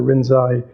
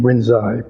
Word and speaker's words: Rinzai: Rinzai, when these teachings from Lin Chi came to Japan, the Japanese have their own way of pronouncing characters, the Chinese characters. So they, in their way of pronouncing, Lin Chi became Rinzai, but it's the same person.